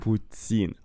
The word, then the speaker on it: путин: Путин. Путин. Правильное ударение на ин.